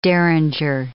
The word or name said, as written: derringer